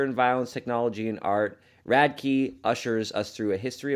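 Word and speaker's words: Radtke: In Seek You, wide-ranging exploration of our lives and public selves, the author Radke, I believe it's pronounced. – Radke? – Radke, thank you.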